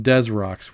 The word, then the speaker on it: Desrocs: It's spelled DESROCS. So when you look at it spelled out that way, you think, oh, it's a pun. It's this rocks, but it's actually pronounced Des Rocks, weirdly enough.